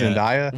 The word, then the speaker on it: Zendaya: Is it Zendaya or Daya? – Zendaya.